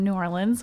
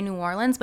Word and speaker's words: New Orleans: They say New Orleans, but they say it like they don't say the R.